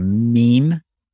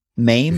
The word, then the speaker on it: meme: Well, my friend and all things travel co-host Ryan sent me a meme. Mame? Mm-hmm. Is it meme or mame? I need my daughter here to correct me. – It's pronounced meme.